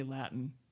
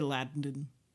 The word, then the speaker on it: Latin: And the next day, and then the day after, and then the day after, I just got all these angry emails about not pronouncing that T, that I had to show Latin, the Latin, its respect. – Well, you and I come from a part of the U.S. where those T's, you cannot pronounce them before an N. You have to say Latin. You can't say Latin.